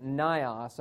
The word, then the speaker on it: Nyos: The disaster centered around a lake called Lake Nyos, I suppose is how you pronounce it, N-Y-O-S.